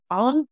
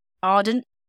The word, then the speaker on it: Arndt: that day, detective, I think this is spelt, this is pronounced, sorry, Ardent. Okay. It's A-R-N-D-T. Ardent. – A-R-N-D-T.